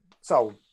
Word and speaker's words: Seoul: So I'm getting how did you pronounce Seoul? It's Seoul, isn't it? – Seoul, yeah.